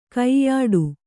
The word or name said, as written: ಕೈಯಿಡು